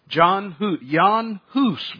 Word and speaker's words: Jan Hus: Jan Hus would be the way it would have been pronounced.